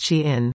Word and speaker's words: SHEIN: Few companies have profited from the pandemic's e-commerce boom more than Shine, pronounced Shein, the retailer that has become known for selling ultra-cheap clothing and other goods.